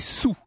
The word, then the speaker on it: sols: D'ailleurs je dis 3 sols parce que ça s'écrit comme ça, mais à l'époque on prononçait sous. On écrivait 3 sols mais on disait 3 sous.